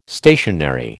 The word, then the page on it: stationery: American English